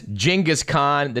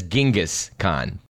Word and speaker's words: Genghis Khan: I want to just state once and for all, it really is Genghis Khan. The name is universally seems mispronounced as Genghis Khan. – Yes, you know, I'm so accustomed to Genghis Khan that I hear it, I don't even notice it anymore. Actually, in Mongolian, it really should be even C-H, Chinggis.